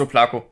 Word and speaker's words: Oblaka: Ich glaub, Ablaka wird es ausgesprochen ja. eigentlich. Aber Gut. In, in Deutschland der, ist es Oplako.